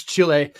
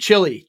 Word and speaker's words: Chile: And so of course, Augusto Pinochet, you have to admire, I pronounce Chile like I do the food. Chili.